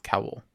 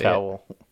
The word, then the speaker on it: cowl: How do you say cowl? – Cowl. – Yeah. Cape and cowl. Cape and cowl. – Are we going to see sleeveless Wolverine? – Like towel, but with K.